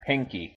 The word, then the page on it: pinky: English